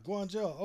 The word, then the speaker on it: Guangzhou: Yeah, Guangzhou. Okay, that's how you pronounce that.